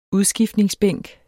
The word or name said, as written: udskiftningsbænk